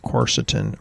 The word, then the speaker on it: quercetin: And then there's this thing called quercetin. Some people pronounce it Quercetin.